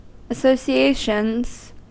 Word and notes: associations: American English

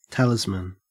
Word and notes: talisman: Australian English

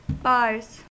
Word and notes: bars: American English

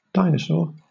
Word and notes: dinosaur: British English

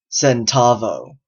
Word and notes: centavo: American English

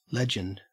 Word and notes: legend: Australian English

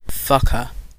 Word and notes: fucker: British English

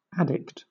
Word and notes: addict: British English; noun

